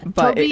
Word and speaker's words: Tobe: So authorities who quickly searched the area did not initially suspect any foul play in the disappearance, with Carroll County Sheriff, is it Toby Liensby? – Yes, it's spelled T-O-B-E. – Yeah, Toby Liensby, yes.